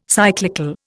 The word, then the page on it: cyclical: Indian